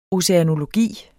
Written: oceanologi

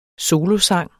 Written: solosang

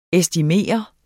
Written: estimeret

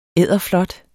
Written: edderflot

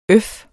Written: øf

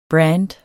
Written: brand

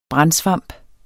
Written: brandsvamp

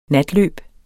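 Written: NATLØB

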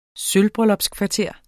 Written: sølvbryllupskvarter

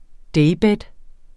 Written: daybed

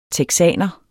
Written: texaner

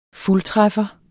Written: fuldtræffer

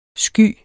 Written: sky

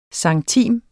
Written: centime

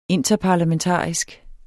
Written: interparlamentarisk

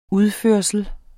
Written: udførsel

